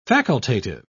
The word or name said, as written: facultative